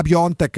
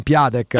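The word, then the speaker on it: Piątek: Io ho scoperto nel, do, due mesi dopo che si pronunciava Piontek e non Piatek.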